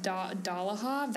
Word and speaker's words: Dolohov: How do you say that? – Dolohov? That's how I always said it. I think that's how Jim Dale says it, so.